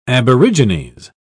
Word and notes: aborigines: British English

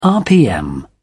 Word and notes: rpm: British English

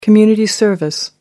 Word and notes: community service: American English

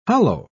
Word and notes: holloa: British English

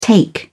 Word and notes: take off: British English

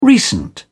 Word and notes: recent: British English

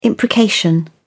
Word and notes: imprecation: British English

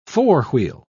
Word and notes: four-wheel: British English

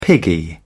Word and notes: piggy: British English

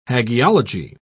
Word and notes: hagiology: British English

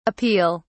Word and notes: appeal: British English

